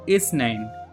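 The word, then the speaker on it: Isnin: ركز على أن الثاء تنطق ساء، اثنين، اثنين، اثنين.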